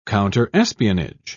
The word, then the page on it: counterespionage: British English